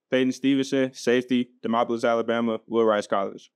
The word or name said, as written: Peyton Stevenson